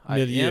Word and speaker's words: milieu: Milieu. – M-I-L-I-E-U.